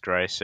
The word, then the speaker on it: Greiss: Sorry, save percentage with three shutouts, and then you have uh, I I can never say his name right. It's Greiss. It's, yeah, Thomas Greiss.